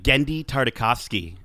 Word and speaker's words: Genndy Tartakovsky: Genndy Tartakovsky.